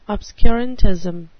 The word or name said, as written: obscurantism